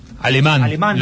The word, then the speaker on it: Alemán: Alemán, lo dijo el propio jugador. – En la segunda. – Alemán, sin tilde. – Acento en la primera.